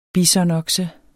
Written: bisonokse

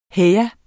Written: heja

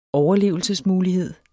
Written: overlevelsesmulighed

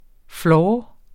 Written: floor

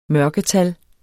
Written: mørketal

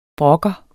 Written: brokker

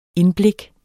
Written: indblik

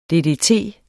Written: ddt

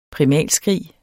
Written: primalskrig

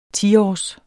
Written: tiårs

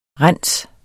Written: rens

